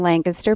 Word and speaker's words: Lancaster: We are in Lancaster, Pennsylvania.